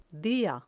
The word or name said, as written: dia